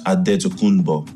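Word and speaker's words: Antetokounmpo: So it's pronounced Adetokunbo in the Greek way. Yeah. But the Nigerian way, the way, you know, I knew my name growing up was Adetokunbo with D and with a B.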